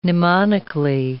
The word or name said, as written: mnemonically